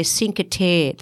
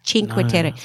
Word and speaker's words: Cinque Terre: And one of the things about Cinque Terre is when people read that, they say Cinque Terre. That's not how you pronounce it. It's Cinque Terre.